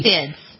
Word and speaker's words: Perseid: Next week comes the Perseid. Is that how you pronounce it? Perseid. – Perseid. – Perseid, thank you.